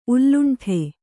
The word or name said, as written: ಉಲ್ಲುಂಠೆ